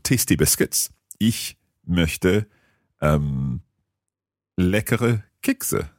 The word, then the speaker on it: Kekse: Kekse. How do you spell that? – K-E-K-S-E. – K-E-K-S-E.